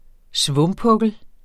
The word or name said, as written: svumpukkel